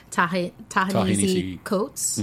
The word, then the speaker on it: Ta-Nehisi Coates: Ta-Nehisi Coates.